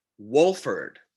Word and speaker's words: Wolford: We pronounce his name correctly, Wolford. – First question in the press conference. Sorry, what's your name again? – I know that my coaches have pronounced it incorrectly, but it's Wolford. Do you actually say the L?